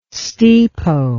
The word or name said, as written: stipo